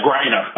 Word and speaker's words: Griner: He needs to call Putin, then get on an airplane, fly to Russia, and bring back Brittany, what is it, Grenier? Is that how you pronounce her last name? – Yeah, Griner. Uh-huh.